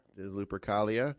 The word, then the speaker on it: Lupercalia: I don't know how to pronounce that. Lupercalia. – That's right.